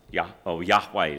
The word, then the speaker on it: Yahweh: Yahweh, as far as we know to pronounce it.